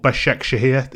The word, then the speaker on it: Başakşehir: Istanbul, Başakşehir.